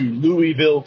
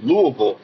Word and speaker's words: Louisville: If you're from the Midwest, you don't go to Louisville, Kentucky. It's Louisville, Kentucky.